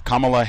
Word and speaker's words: Kamala: Biden's VP pick, why Kamala Harris embraces her biracial roots. So this is August 11, BBC. My name is pronounced Kamala, like the punctuation mark.